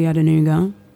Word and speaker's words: Adenuga: Um, but if you want to find me on the internet, it's just everything is Julie Adenuga. Um, my surname is spelled exactly how it sounds.